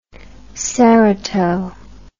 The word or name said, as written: cerato